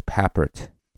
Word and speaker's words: Papert: Ines Papert. Ines tried to teach me how to say it. – Ines Papert or Ines Peppard. Perfect.